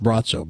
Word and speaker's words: Braco: It sounds like it would be Braco or Braco, but it's pronounced Brazzo.